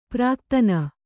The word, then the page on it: ಪ್ರಾಕ್ತನ: prāktana; Kannada